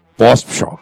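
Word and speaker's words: Pospíchal: Na verdade, eu vou pronunciar em tcheco. Tá. Pospichal.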